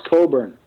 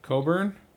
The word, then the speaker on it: Cockburn: It's pronounced Coburn. – Coburn? – It's the Scottish pronunciation, yes.